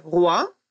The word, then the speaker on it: rois: «Les rois» – «короли», «le roi» – «король». Давайте запомним, что для обозначения множественного числа на конце слова французы всегда добавляют букву «s», но она никогда не произносится.